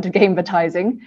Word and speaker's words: gamevertising: Yeah, so working in forecasting, we tend to like to mesh words together, hence gaming and advertising melding together to create the word gamevertising, which you pronounced correctly.